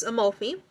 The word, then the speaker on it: Amalfi: So Amalfi, I'm not sure how I pronounced it earlier, but it's Amalfi.